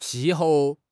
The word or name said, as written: qi4 hou4